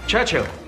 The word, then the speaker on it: Churchill: Churchill? That's how you say it, all together like that. I'd heard church and hill, but couldn't find either.